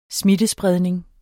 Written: smittespredning

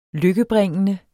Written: lykkebringende

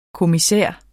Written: kommissær

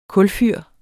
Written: kulfyr

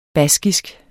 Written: baskisk

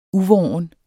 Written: uvorn

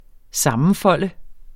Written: sammenfolde

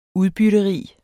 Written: UDBYTTERIGT

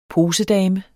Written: posedame

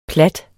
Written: platte

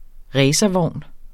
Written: racervogn

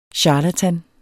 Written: CHARLATANS

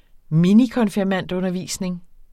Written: minikonfirmandundervisning

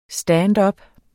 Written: standup

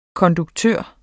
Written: konduktør

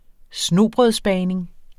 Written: snobrødsbagning

